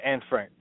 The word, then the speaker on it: Anne Frank: Yeah, Anne Frank.